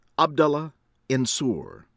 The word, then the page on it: Abdullah Ensour: American English